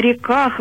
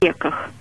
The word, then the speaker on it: реках: Коль скоро название взято из псалма, то надо читать так, как это в церковно-славянском языке. На реках вавилонских, а не на реках.